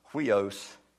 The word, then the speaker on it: huios: And this word here is, according to my phonetic spelling, is huios. It's spelled H-Y-I-O-S.